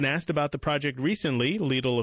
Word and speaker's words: Lidl: And excuse me, that's pronounced Lidl. It's the grocery store.